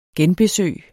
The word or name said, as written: genbesøg